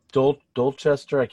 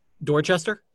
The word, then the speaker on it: Dorchester: Dorchester, that's not the correct pronunciation. Another house. – Dorchester? – Thank you.